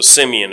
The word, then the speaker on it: Simeon: And Simon, depending on your pronunciation of the word, it's got an E, so Simeon is probably closer to right.